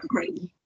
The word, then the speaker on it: Crichton: Crichton.